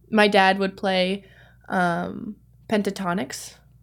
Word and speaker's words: Pentatonix: Not actually how you say it. I oh, it is. That is how you say it. – Oh, it is. He, yeah, see, he's messed correct, my brain up. he calls it pentonics.